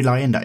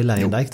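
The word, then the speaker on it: Luyendyk: Ari Lyondike. Ari Lyondike. Det har blivit väldigt eh, amerikaniserat. Ari Lyondike där.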